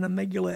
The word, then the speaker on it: amygdala: So this thing, let's get back to this amygdala. I said the wrong syllable. I had it on. – Amygdala.